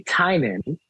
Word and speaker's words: Tynion: So I think I heard you say, is his last name pronounced Tynion? – So it's actually Tynion. And he Tynion. Okay. Tynion. – Yeah. Yeah. I used to call it Tynion. And then I heard an interview with him, and he said Tynion, and he said his name looks like it's spelling tiny onion.